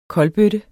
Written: kolbøtte

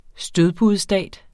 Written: stødpudestat

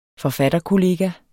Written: forfatterkollega

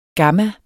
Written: gamma

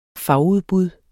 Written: fagudbud

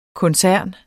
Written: koncern

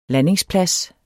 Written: landingsplads

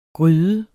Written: gryde

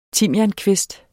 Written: timiankvist

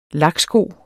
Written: laksko

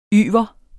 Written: YVERET

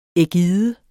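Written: ægider

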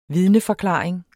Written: vidneforklaring

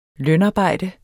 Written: lønarbejde

